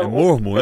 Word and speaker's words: mormo: Como está a situação do mormo aqui no DF? – Do quê? – Do mormo, que é aquela doença que ataca os equinos. – Ah, do mormo, né? – É mormo, a pronúncia certa.